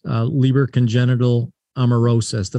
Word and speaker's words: Leber congenital amaurosis: to address Leber congenital amaurosis. Did I pronounce that correctly? – Yes.